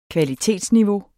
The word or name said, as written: kvalitetsniveau